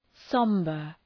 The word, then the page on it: sombre: ‘sɒmbər